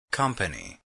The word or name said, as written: company